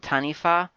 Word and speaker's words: taniwha: It's apparently called Tanifa, which is kind of weird because it's spelled Tanifwa, T-A-N-I-W-H-A, but I'm assuming it's in Te Reo,